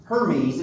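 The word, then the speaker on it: Hermès: And it's spelled H-E-R-M-E-S, but not pronounced Hermes. It's pronounced Hermes, you know.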